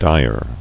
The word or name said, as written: Direly